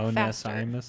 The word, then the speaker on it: Onesimus: I want to make sure I say his name right. Onesimus. – I'm sure that is exactly. – I actually looked it up. – Say it Onesimus.